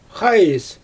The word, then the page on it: xaajs: Xyzyl